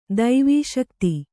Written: ದವಸಗಿತಿ

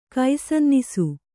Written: ಕೈಸನ್ನಿಸು

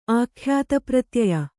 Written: ಆಖ್ಯಾತಪ್ರತ್ಯಯ

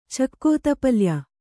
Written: ಚಕ್ಕೋತಪಲ್ಯ

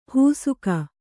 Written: ಹೂಸುಕ

